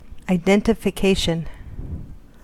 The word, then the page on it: identification: American English